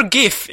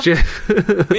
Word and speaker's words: Jif: A full bar of Jif peanut butter, or Gif if you want to say it wrong. – I think it is Jif.